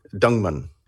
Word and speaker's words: Dingemans: And then the family name, D-I-N-G-E-M-A-N-S. Now, I'm assuming because of the French pronunciation, the S is silent. So Dingmann.